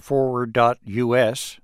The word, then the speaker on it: FWD.us: Rob Jessmer is campaign manager for Forward.us, that's spelled FWD.us, a group that's founded to try to mobilize the tech community to change the U.S.